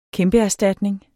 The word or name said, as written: kæmpeerstatning